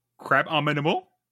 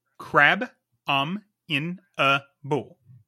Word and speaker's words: Crabominable: Crab-ominable? – Crab-om-in-a-bull. – There you go. – Crabominable. – It's a tongue twister. Crabominable. – Crabominable. – Like abominable. – Crabominable. – Put crab in front.